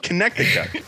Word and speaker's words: Connecticut: It's spelled Connecticut.